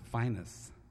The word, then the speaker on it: Phineas: You knew him as Phineas Newborn, but down in Memphis, the word Phineas, which is spelled P H I N E A S, is pronounced finest.